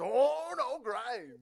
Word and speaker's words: Ono Grindz: So how do you say it? Ono Grindz.